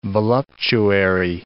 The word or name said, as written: voluptuary